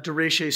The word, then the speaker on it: derecho: I know this past week we had that derecho storm. Did I say that right? Derecho? Is that how you say it? Derecho. What? Derecho. I wasn't even close.